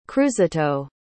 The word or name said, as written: cruzito